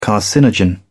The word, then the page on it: carcinogen: British English